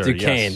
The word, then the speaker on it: Duquesne: How's it pronounced? Duquesne? – I believe so, sir. – Duquesne. – Yes.